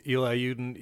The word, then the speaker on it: Eli Yudin: You can find me on Twitter at just my name, Eli Yudin, E-L-I-Y-U-D-I-N.